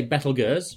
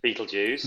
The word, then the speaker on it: Betelgeuse: So I say Betelgeuse, you say Betelgeuse. – There we go. Other people will say something in between, I'm sure.